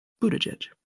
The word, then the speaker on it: Buttigieg: So, Buttigieg.